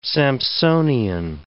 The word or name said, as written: Samsonian